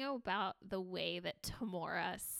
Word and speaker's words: Tamora: So her name is spelled T-A-M-O-R-A. – Tamora. No. – So, and I have heard like multiple people, including Allison, pronounce it as Tamora. And honestly, at first I just assumed that I was wrong in calling her Tamra. – Because I'm older and I have more lived experience. – That was like, I just had always called her that. And so when I was like, oh, I'm pronouncing her name wrong.